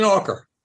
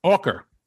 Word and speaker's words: Auker: At the beginning of the book, you start with Eldon, and I hope I pronounce his last name right, Eldon Auker. – Auker.